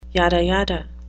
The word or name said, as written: yada yada